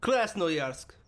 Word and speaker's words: Krasnoyarsk: He pronounced that phonetically. I don't think there's any better way to pronounce that unless you're Russian. – Krasnoyarsk. – It sounds better. – Krasnoyarsk Krai. – Yeah, that's it.